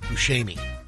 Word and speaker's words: Buscemi: No, it's Buscemi. – I've always said Buscemi. – That's what I've always said. – The proper Italian pronunciation is Buscemi.